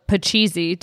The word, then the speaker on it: pachisi: We played Parcheesi, which is often also Pachisi, depending on which version you have. – Pachisi. – Legitimately, some boxes say Pachisi, and some say parcheesi, and I always thought it was my parents saying it wrong, but as it turns out, no.